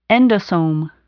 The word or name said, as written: endosome